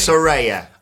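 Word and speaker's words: Saraya: Weird probably isn't the word, but a lot of people, when people like Soraya, I get the pronunciation wrong every time. When people like the former Paige come back to wrestling. – Soraya. That's what we're sticking with.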